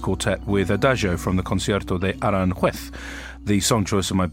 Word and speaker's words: Concierto de Aranjuez: And it's called Concierto de Aranjuez, I yes.